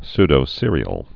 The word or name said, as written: pseudocereals